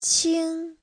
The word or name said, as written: qing1